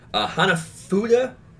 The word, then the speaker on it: Hanafuda: Yes, I believe it's actually pronounced, anyone who wants to definitely tell me otherwise, that's fine. Hanafuda playing cards. It means flower cards.